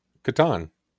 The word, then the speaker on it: Catan: Catan. It has to be Catan.